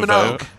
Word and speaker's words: Minogue: Up quickly that you completely butchered the pronunciation of the great Kylie Minogue? You added a second G in there.